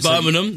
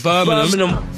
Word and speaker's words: Birmingham: So, Birmingham, that's how you say it. – Birmingham.